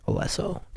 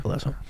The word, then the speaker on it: Alesso: The Big Room House, a couple things by Chainsmokers and Alesso. Did I say his name right? Alesso? – Yeah. – Alesso.